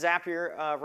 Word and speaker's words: Zapier: We have about 2.5 million users, and yes, Zapier rhymes with happier, in case you were curious.